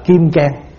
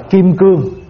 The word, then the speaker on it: kim cang: gọi là kim cang, có khi đọc là kim cương. Bây giờ quý vị nghe chữ kim cương thường hơn là kim cang. Kim cương và kim cang là một chữ, và nó là một loại đá cứng khó đập vỡ.